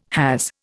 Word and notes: has: Indian

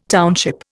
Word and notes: township: Indian

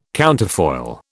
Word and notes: counterfoil: British English

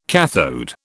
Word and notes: kathode: British English

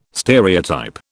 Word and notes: stereotype: British English

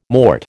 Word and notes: mort: American English

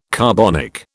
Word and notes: carbonic: British English

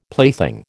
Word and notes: plaything: American English

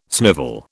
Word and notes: snivel: British English

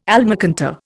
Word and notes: almacantar: Indian

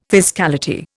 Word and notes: fiscality: Indian

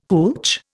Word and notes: hootch: Indian

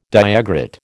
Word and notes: diagrid: American English

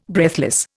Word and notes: breathless: Indian

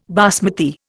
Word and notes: basmati: Indian

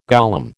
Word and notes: golem: American English

